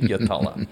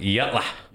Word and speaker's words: Yatala: what do you mean Yatala? and they pronounce it Yatla. – Yatla. – Yatla. Yatla, mate.